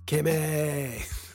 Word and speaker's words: Kimi: Kimi. That's how you say it. When I hear Kimi, makes you think of Timmy from South Park.